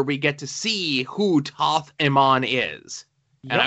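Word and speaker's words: Thoth-Amon: Toth, the way I've been told is pronounced is Toth Amon.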